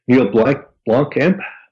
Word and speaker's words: Neil Blomkamp: For example, in the shot that you're talking about in Elysium, one of the challenges they had was that Neil Blomkamp, I'm not sure how to say the name.